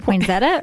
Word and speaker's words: poinsettia: Is it poinsettia or poinsettia? – well, there's no z in there. It's an s.